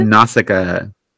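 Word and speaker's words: Nausicaä: And then you said Nausicaa? Is that how you say it? – Nausicaa.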